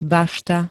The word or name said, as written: bašta